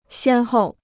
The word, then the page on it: 先后: xiān hòu